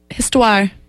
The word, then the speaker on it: Histoire: Histoire.